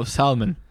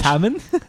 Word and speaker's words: salmon: Oh, Salmon. – You're not supposed to pronounce the L. – You're not? – The L is silent. – It's silent. It's French. – Salmon? – It's French.